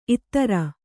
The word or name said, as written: ಇತ್ತೆರ